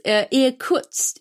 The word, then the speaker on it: Irkutsk: So the next stop on the train where you got off, or in the book they got off, was Irkutsk.